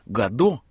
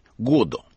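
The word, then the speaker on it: Godot: Даже имя Гадо, как мы привыкли произносить, у него звучит так, как произносил сам Беккет с ударением на первом слоге – Годо.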